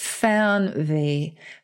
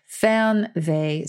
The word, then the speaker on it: Fernweh: And it's Fernweh. Fernweh. So poetic in German. It's spelled Fernweh. And it means the longing to be far away.